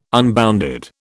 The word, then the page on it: unbounded: British English